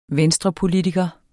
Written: venstrepolitiker

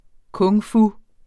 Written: kung-fu

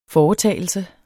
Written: foretagelse

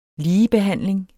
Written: ligebehandling

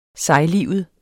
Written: sejlivet

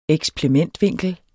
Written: eksplementvinkel

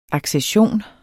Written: ACCESSIONERNES

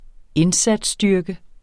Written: indsatsstyrke

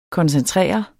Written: koncentrere

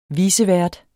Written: vicevært

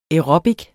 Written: aerobic

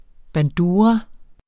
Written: bandura

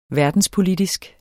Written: verdenspolitisk

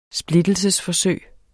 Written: split

